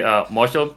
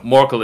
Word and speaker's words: Morchel: You can actually say Morshall if you want like the proper pronunciation, but Morshall. No, Morkel is perfectly fine.